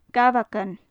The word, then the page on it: gabhagan: gaelic